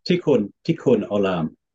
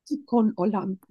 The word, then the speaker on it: Tikkun Olam: And gave a wonderful talk about the concept of Tikkun Olam. Am I saying it right? – Tikkun Olam? Tikkun Olam. – Tikkun Olam. Mm-hmm.